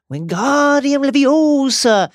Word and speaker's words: Wingardium Leviosa: Wingardium Leviosa, he shouted, waving his long arms like a windmill. You're saying it wrong, Harry heard Hermione snap. Of course. It's Wingardium Leviosa. Make the gah nice and long.